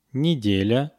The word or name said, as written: nedelya